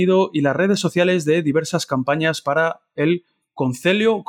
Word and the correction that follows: Concello: Concello, Concello de Santiago, ¿lo he pronunciado bien? ¿Cómo? ¿Concelo? – Concello? Perfectamente, Concello de Santiago.